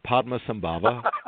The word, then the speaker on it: Padmasambhava: And it's called the Portland Pama Sabla Buddhist Center. Did I get that right, Paul? – Not really, but that was close. Padmasambhava Buddhist Center.